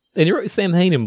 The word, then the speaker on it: Samhain: And he's written Samhain, or Samhain, as you would actually say it, on a chalkboard in blood.